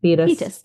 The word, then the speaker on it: Thetis: To jump into Achilles' parentage, he is born of a mortal man, and a goddess is his mother, a sea nymph named Thetis. Is that correct? – Yeah, Thetis. I listened to the audiobook, so I can help us out with some pronunciation.